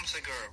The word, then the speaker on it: Segura: Segura? – Yeah, Tom Segura, bro.